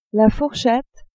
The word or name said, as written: fourchette